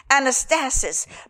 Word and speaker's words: anastasis: Anastasis, pronounced anastasis.